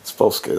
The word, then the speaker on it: Spolsky: Spolsky, that's it.